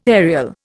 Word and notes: aerial: Indian

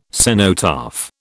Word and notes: cenotaph: British English

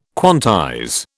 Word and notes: quantize: British English

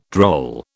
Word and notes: droll: British English

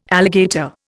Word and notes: alligator: Indian

